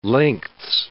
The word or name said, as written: lengths